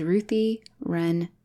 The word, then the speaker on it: Ruthie Wren: is Ruthie Wren again. So it rhymes and it's just me again. Cause I really worked hard to be Ruthie and I'm not letting it go. So it's Ruthie Run again.